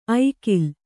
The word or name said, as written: ಐಗಳ